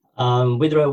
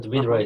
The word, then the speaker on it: Widerøe: How it should be pronounced? Wideroe? – Wideroe, we say in Norwegian, Wideroe, but Wideroe is perfectly fine.